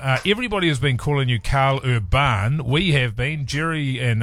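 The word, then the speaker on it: Karl Urban: It is just Carl Urban, for the record, but over in Europe, which is where half of my family originated from, it's pronounced the other way. So, I just say it the way it looks.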